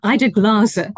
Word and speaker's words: Ida Glaser: Ida Glaser.